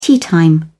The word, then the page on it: teatime: British English